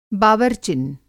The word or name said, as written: बावरचिन